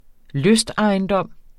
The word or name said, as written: lystejendom